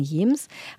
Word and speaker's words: James: Du hast ja schon am Anfang gesagt, die Helgoländer sagen Jems.